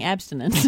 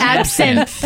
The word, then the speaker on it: absinthe: You are all saying, it sounds like you're saying abstinence. – It's absinthe.